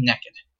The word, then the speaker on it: nekkid: She asks Bart where his bathing suit is, and Bart responds that he's going to be swimming naked. That's exactly how he pronounces it, too. Naked. N-E-K-K-I-D.